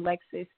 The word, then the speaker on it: LXXS: and then my name Lexis, LXXS.